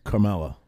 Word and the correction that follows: Kamala: I'm trying to be disrespectful, but isn't it Carmella? I think it's Kamala.